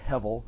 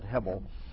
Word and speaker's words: hebel: So today's actually an introduction to Ecclesiastes, because we're going to focus on the word hebel, or hevel. I'm going to pronounce it the way it looks when you spell it, hebel, which is really literally breath or puff of air.